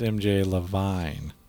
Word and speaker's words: Levine: We mispronounced the... we said MJ Levine, and it's MJ Levine. – Oh. – So that's... – Yeah. – That's an honest mistake, though. – It's spelled Levine.